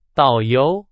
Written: 导游

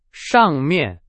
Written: 上面

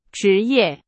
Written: 职业

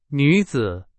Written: 女子

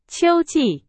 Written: 秋季

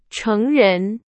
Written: 成人

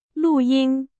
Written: 录音

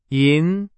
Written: 银

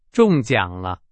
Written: 中奖

